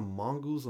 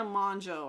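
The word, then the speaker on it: Mongeau: Tana Mongoose or Managoose or whatever. – Tana Monjo, I believe is how you actually pronounce it.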